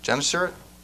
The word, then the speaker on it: Gennesaret: lake of Gennesaret? Is that how it's pronounced? Yeah, that sounds good.